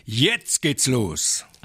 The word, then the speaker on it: jetzt geht's los: Par contre, c'est « Yetz Getz Los ». Je l'ai bien prononcé, à peu près. – Tu l'as à peu près bien prononcé. En vrai alsacien, ich jetzt geht's los.